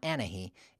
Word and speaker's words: Anahi: The letter H in Spanish is silent, always. There's no exceptions. It's always silent. So my daughter, for example, her name is Anahi, and it's spelled A-N-A-H-I. It's not Anahi. It's not Anahi. It's Anahi, and the H is silent.